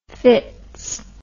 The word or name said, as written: fitz